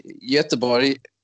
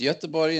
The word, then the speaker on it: Göteborg: No, well, in Norwegian, but in Swedish it would have an O with two dots on top, but it's pronounced the same. – Oh, okay. Okay. – So in Swedish, Göteborg is Ö, Göteborg, and it's Göteborg in Norwegian, but with a different letter.